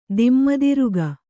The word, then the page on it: ದಿಮ್ಮುದಿರುಗ: dimmudiruga